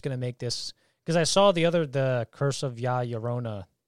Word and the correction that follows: La Llorona: or however you pronounce it. – La Llorona.